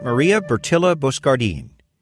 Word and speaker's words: Bertilla Boscardin: Bertilla Boscardin. Let's see what that sounds like. Pronounced the correct way. – This is Franciscan Media's Saint of the Day for February 26th. Today we celebrate Saint Maria Bertilla Boscardin.